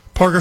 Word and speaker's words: Parker: Is it Mims Galloway or whatever going up against Anise Porker? Am I saying that correctly? Is it Porker, Parker, Porker? Parker, who looks,